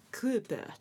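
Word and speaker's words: Körper: その中のクーパー発音難しい、そうケーパーやクーパー、ケとクの間のクっていうクーパーっていう、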